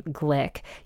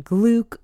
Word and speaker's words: Glück: And I just learned that she pronounces her last name Glick. You'll probably hear it Gluck or Gluck often, but yes, Louise Glück is her preferred pronunciation.